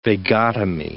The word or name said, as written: vagotomy